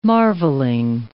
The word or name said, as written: marvelling